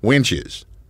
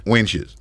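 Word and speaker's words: Wanchese: We were actually heading to the Outer Banks, and a place in North Carolina called Winches. – Okay. – Winches. – Winches. – And that's the way they kind of pronounce it, but it's like the Mecca.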